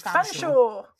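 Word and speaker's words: Featherstonehaugh: Written Featherstone Howe, but pronounced Fanshawe.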